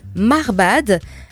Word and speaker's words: Marbhadh: Voilà, ça s'appelle Marbad. Alors ça s'écrit Marbhadh.